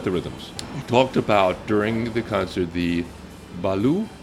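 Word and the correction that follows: Yanvalou: rhythm, I don't know if I'm saying it right, featured in the piece titled Moonlight in Haiti. – Okay, that was Yan Valu.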